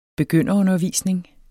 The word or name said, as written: begynderundervisning